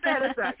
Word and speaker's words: Banaszak: Banaszak.